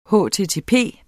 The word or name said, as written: http